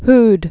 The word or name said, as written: who'd